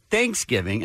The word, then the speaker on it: Thanksgiving: She's saying the difference between Thanksgiving and Thanksgiving, mm-hmm. right? – Yeah. Mm-hmm. You put the emphasis on the thanks. – That's right. – I put the emphasis on the giving.